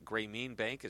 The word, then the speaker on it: Grameen Bank: And I'm not familiar with, is it Grameen Bank? Is that how you say it in Bangladesh? – Correct. – Yep. – Correct. – So I said that right.